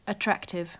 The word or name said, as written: attractive